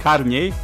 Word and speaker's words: Karniej: Karniej, but it's okay.